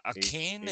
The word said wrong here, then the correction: Achane: is that how you say his name? – Achane.